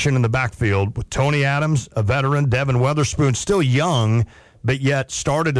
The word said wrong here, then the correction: Witherspoon: And it's Witherspoon, not Weather. I always say that wrong.